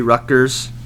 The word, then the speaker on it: Rutgers: Until I was 18 years old, I thought that Rutgers was pronounced Rutgers, R U G T rather than R U T G. I don't know why, but I remember vividly where I was when someone told me I was pronouncing it wrong. I was mind blown. – It is Rutgers, named after Henry Rutgers, and it is the official state university of New Jersey.